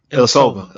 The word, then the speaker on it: Ilyasova: Ilyasova.